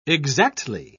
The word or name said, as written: exactly